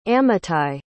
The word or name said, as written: ammitai